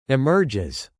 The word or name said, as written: emerges